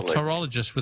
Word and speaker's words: pterologist: A pterologist with a silent P.